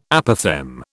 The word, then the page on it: Apophthegm: British English